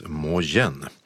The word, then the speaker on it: Mo Yan: Och Nobelpristagaren stavar sitt namn M-O-Y-A-N och det ska tydligen uttalas Mojen.